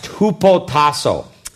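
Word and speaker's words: hupotasso (ὑποτάσσω): The biblical word for submission in the New Testament is a Greek term that is pronounced hupotasso. Das biblische Wort für Unterordnung ist das griechische Wort hypotasso.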